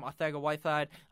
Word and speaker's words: Arcega-Whiteside: That's how you say his name, Arcega-Whiteside.